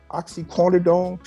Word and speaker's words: oxycodone: According to TMZ, officers claim that they found 31 oxycodone pill.